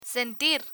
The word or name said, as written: sentir